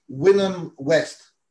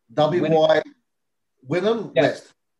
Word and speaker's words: Wynnum West: How do I properly pronounce the name of the little area of Brisbane that you live in? – Wynnum West. Wynnum West.